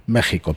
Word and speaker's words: México: México, perdón, que la X en, mexicano se pronuncia como una J.